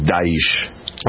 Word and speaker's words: Daesh: I say Daesh. – I think Daesh. – We looked it up and it was Daesh. – Right. Well, that's the way this Iraqi general pronounced it when he was being interviewed.